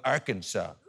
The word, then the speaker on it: Arkansas: Why is it pronounced Arkansas, but it's spelled Arkansas? It's pronounced Arkansas, but it's spelled Arkansas. There's an S where there should be a W. Where's the W? Where's the W, ladies and gentlemen?